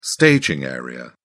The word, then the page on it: staging area: British English